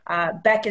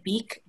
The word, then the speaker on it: Beeck: So Beck is spelled B-E-E-C-K. So it looks like beak, but it is the Beck Center.